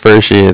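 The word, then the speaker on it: Pferschy: My last name is Fershi.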